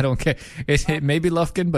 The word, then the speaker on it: Lufkin: I think it's pronounced Lufkin, by the way. – Didn't I say Lufkin? – No, you said Lufkin.